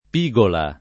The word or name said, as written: pigola